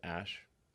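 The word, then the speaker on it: Æ: I mean, it's just X, the letter X, um, and then the AE is like pronounced ash.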